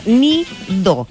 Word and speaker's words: NeeDoh: Ahora, este producto, como les dije yo anteriormente, se llama Nido. Esto es se escribe N-E-E-D-O-H, que fabrica la marca estadounidense Chilling.